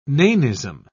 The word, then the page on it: nanism: British English